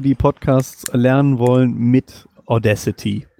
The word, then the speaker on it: Audacity: Genau, man spricht es richtig Audacity aus.